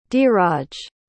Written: deeraj

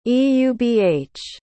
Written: eubh

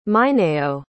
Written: mineyo

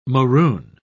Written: maroon

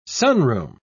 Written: sunroom

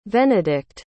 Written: venedict

